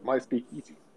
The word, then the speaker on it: MySpeakeasy: MySpeakeasy.